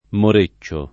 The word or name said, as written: moreccio